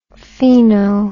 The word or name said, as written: pheno